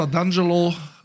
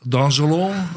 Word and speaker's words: D'Angelo: Also wie gesagt, Neustart bei Mielke unter Casa d'Angelo, d'Angelo. – Das ist kein Franzose. – Kann man mal hingehen. Wie spricht man Italienisch aus dann? – D'Angelo. – D'Angelo. – So muss man das sagen.